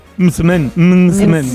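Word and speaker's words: msemen: Mzmen, ¿vale? Y se pronuncia como lo ha hecho Hakima. Pero nunca, nunca se pronuncia como lo vamos a escuchar en boca de este TikToker, porque puede ocasionar confusiones. Lo escuchamos.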